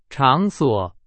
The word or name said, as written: 场所